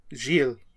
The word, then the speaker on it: Gilles: How do you pronounce Grimondi's first name? – Gilles. – Yeah, it's how we're spelling it on here, Ryan. Like a farm.